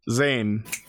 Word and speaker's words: Zane: It's pronounced just like the Power Rangers in space character. Zane.